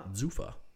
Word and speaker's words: G'Suffa: Zufa.